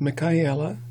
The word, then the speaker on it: Michaela: So the pronunciation is Michaela. It's actually meant to be Hebrew, but it's pronounced the same way in Spanish and Italian.